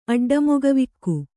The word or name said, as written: ಅಡ್ಡಮೊಗವಿಕ್ಕು